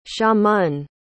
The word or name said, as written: xiamen